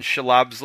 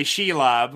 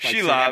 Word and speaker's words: Shelob: Usually people say Shelob. Like Shelob. – Emphasis on the she.